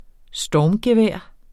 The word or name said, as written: stormgevær